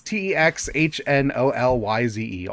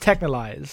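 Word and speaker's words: Texhnolyze: It's Technolyze, all capital letters. – Technolyze? – Yeah, maybe, sure. – Is intended to be pronounced Technolyze, so you were right the first time.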